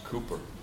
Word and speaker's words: Cowper: spelled Cowper, but it's pronounced Cooper, served his congregation.